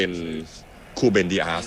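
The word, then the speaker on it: Rúben Dias: เจ้าตัวได้ออกเสียงว่าเป็นคูเบนดิอาสนะพี่น้องตัว R ของประเทศโปรตุเกสเนี่ยออกเสียงเป็นตัว K นะฮะ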